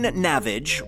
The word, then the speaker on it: Navage: Henderson, Chelsea M., Jeff Baker, Bridget Porter, Aaron Navage, rhymes with savage, buy me a cocktail and try not to ravage, Patrick Connolly, Taylor Williams, and Funkasaurus Rex.